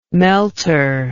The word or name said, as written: melter